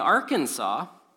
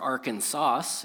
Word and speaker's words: Arkansas: But in Arkansas, or Arkansas, if you want to pronounce it how it's spelled.